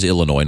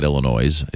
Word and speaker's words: Illinois: But it is Illinois, not Illinois, as you know.